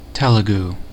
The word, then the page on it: Telugu: /ˈtɛl.ə.ɡuː/